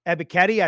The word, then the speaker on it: Ebiketie: When you talk about guys like the Penn Staters, Jesse Lucada, Arnold Ebiketie, I say that right? – You got it.